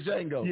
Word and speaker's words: Django: That was like Django saying, the D is silent. – Right, it was a D. – It was a D in front of the J. – The Django.